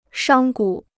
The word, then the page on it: 商贾: shāng gǔ